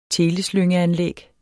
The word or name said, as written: teleslyngeanlæg